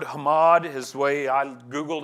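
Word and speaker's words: hamad (חמד): hamad. It's spelled, it's more like C-H-A-M-A-D, but it's pronounced Hamad.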